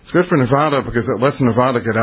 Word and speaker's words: Nevada: Nevada, I got to say it right, Nevada. They always get mad at me in Nevada if I say Nevada. It's always attaboy, Nevada, atta, Nevada. There we go.